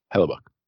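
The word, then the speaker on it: Hellebuyck: He led the league in shots against twice, which is tied fourth most ever behind Tony Esposito, Gump Worsley, and Connor Hellebuck. How do you pronounce his name? Hellebuck? Hellebuck. Oh, I said it right the first time.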